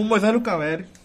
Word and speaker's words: Moisés Lucarelli: No Moisés Lucarelli.